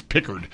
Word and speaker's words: Pickard: I said Picard. It's so hard to not use the Star Wars guy's pronunciation, but it's Pickard.